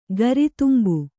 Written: ಗರಿತುಂಬು

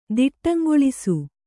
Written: ದಿಟ್ಟಂಗೊಳಿಸು

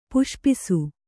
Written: ಪುಷ್ಪಿಸು

